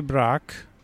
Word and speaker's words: Brach: Jedna książka to książka Tary Brak, pisze się Brach przez CH jak Pod, Brachu.